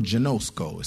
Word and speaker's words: ginosko: Now that word known right there is the Greek word genosko. It's spelled G-I-N-O-S-K-O, genosko, like Bosco.